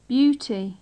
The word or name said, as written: beauty